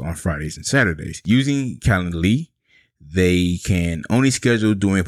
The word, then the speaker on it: Calendly: It's Calendly. I can never say that right. Calendly.